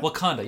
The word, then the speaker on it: Wakanda: Wakanda? – Wakanda, yeah.